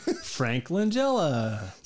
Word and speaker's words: Langella: It's Frank Langella, right? – Frank Langella. – See, I knew that.